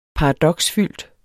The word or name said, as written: paradoksfyldt